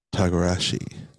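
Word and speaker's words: togarashi: glazed barbecue pork belly with furake takajari mac. Tagarashi mac salad. Yes, that's how you say it.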